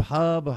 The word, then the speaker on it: Hub: I wanted to ask you about the Hube Hub, however you pronounce it, Arkush, the AP. – Yeah, Hub. Okay, Hub Arkush,